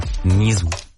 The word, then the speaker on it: NYZZU: Ausgesprochen wird es Nisu, aber geschrieben wird es NYZZU. Schwer zugänglich. NYZZU. Nisu.